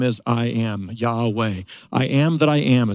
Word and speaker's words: Yahweh: And let me just remind you, when you see the word Lord, all caps, the actual Hebrew word is Yahweh, sometimes mispronounced as Jehovah, but Yahweh in Hebrew.